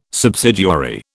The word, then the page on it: subsidiary: British English